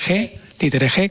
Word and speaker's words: Jerez: Títeres Jerez, con ese acento en la E.